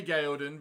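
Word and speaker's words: Gaeldan: And I can say Gaelden because they said it in the first episode, so I know the correct pronunciation.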